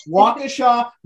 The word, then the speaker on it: Waukesha: Is it Waukesha. I'm sorry. I'm getting the enunciation correct. Waukesha, Wisconsin.